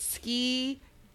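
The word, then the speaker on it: skedaddle: Because of the syllables. It's ski-dad-dole. – So it's got three.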